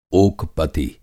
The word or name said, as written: ओक पति